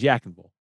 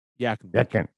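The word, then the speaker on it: Yadkinville: Where I live is spelled Y-A-D-K-I-N. – Yadkin, right? Yadkin. – Now, that's how you would pronounce it. Anybody who lives here says Yakinville. Yakinville. – Yakinville. – You got to say it real quick.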